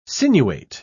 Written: sinuate

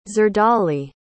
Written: zerdali

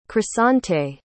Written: croissante

